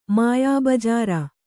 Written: ಮಾಯಾಬಜಾರ

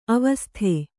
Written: ಆವಸಿತ

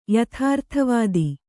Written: ಯಥಾರ್ಥವಾದಿ

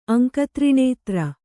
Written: ಅಂಕತ್ರಿಣೇತ್ರ

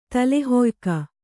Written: ತಲೆಹೊಯ್ಕ

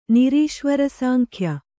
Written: ನಿರೀಶ್ವರಸಾಂಖ್ಯ

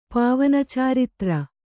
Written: ಪಾವನಚಾರಿತ್ರ

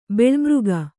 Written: ಬಲಿಮುಖ

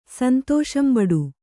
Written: ಸಂತೋಷಂಬಡು

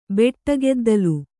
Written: ಬೆಟ್ಟಗೆದ್ದಲು

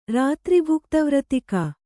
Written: ರಾತ್ರಿಭುಕ್ತವ್ರತಿಕ

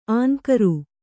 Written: ಅಂಕುರ

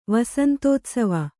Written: ವಸಂತೋತ್ಸವ

